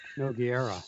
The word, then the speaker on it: Nogueira: Something like that, because I butchered Carl Nogueira.